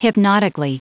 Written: hypnotically

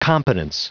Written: competence

